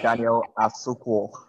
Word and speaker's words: Daniel Asuquo: And it's Daniel Asuquo, right? Am I saying it right? – Yeah, Daniel Asuquo. – Asuquo, yes.